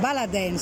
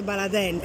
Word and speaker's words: Baladin: Siamo allo stand Baladin, si dice Baladin, è scritto Baladin ma si dice Baladin. – C'è chi lo pronuncia in entrambi i modi.